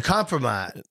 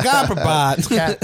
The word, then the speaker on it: kompromat: It's a kompromat. – Yeah. We definitely both said it wrong. Yeah, yeah. It's a kompromat.